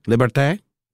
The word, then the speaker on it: liberté: Did I say that right? Liberté? – La liberté.